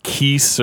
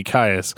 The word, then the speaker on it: Caius: But I heard somebody calling him like Keese or... But I was like, no, that's too much like Kiss. It's got to be Caius.